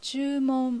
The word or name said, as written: Chuumon